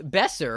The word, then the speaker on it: Besser: Derek Besser rhymes with lesser.